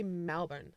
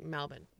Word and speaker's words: Melbourne: So I lived in Melbourne, Australia for a bit. – Hold on there. This is what cracks me up. So I was just in Australia, and like people from Australia, when they say Melbourne, it sounds like Melbourne.